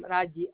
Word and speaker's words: Raji: Raji, Raji.